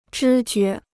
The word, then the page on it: 知觉: zhī jué